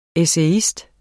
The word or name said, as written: ESSAYISTERNES